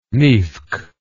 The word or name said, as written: nivkh